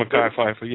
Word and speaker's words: Mekhi Phifer: something so easily found in scriptures, somebody deciding to spend big money, get Danny Glover, and am I saying his name right, Mekhi Phifer? – Mm-hmm. Mekhi Phifer, yeah.